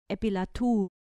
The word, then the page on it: epilatu: grec